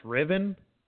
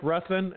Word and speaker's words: Ruthven: It's spelled Ruthven, but I guess I was looking that up, and I guess it's actually pronounced Riven.